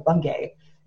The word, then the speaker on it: Bungay: Bungay.